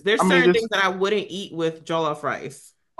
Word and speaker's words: jollof: Oh, that's how you say it. Jollof. How do you Jollof? – jollof? – How do you say it then? – Jollof. – Low key, I just kind of said jolly rice.